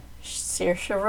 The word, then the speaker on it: Saoirse: Well, this one has, Saoirse Ronan. – Saoirse Ronan, good pronunciation.